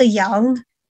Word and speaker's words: Yang: Now, moving on to another Asian-American sci-fi movie, A24 also has a film out called After Yang, or After Yang, if you want to pronounce it properly, like a Chinese person.